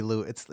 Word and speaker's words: Lewis: I don't know how to pronounce his name because it's spelled Lewis, but they reference Louis Armstrong. – I think it's got to be Louis. It's Louis for sure.